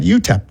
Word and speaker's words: UTEP: Is that how you say it? UTEP? – Yeah, I mean, that's how you say it. Texas El Paso, but yeah, UTEP.